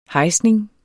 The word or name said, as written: hejsning